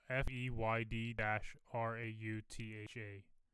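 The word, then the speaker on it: Feyd-Rautha: Is that how you say it? It's Feyd dash Rautha.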